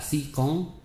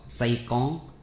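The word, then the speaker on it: Si Cón (西貢): dùng danh từ Tây Cống mà họ phát âm nghe ra là Si Cón hoặc Say Cón theo giọng Quảng.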